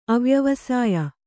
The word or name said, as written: ಅವ್ಯವಸಾಯಿ